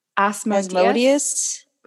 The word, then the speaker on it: Asmodeus: And during the exorcism, Madeline's low, grunty, possessed voice said that she was possessed by the demons Beelzebub, Leviathan, Baalberith, Asmodeus. This rhymes with Amadeus Mozart.